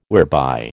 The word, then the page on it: whereby: American English